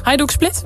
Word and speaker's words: Hajduk Split: Hajduk Split? – Ja hoor.